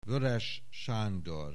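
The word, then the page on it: Weoeres Sandor: Hungarian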